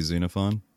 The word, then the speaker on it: Xenophon: That starts with an X, Z- but pronounce it as a Z. Xenophon.